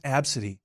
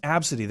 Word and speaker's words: Abcde: And they try to say, oh, no, it's pronounced Absidy. ABC, it's pronounced Absidy. There's only one, there's two vowels, but there's, Absidy. That's how it's pronounced.